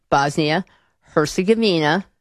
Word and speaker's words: Bosnia-Herzegovina: By the way, that is the pronunciation, Bosnia-Herzegovina.